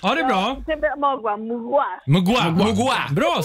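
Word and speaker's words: Mgła: Och jag, jag har fått lära mig att det uttalas mogwa och betyder dimma.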